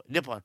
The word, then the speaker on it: Nippon: And Air Nippon, is that how you say it? Nippon? Not unlike Patty LaPone? No, Nippon.